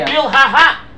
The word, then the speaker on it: Bilhah: But that's why the hay is not pronounced in Bilhah. You should say Bilhah.